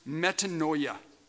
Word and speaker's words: metanoia: Well, the word repentance in the Greek is a word that's pronounced metanoia.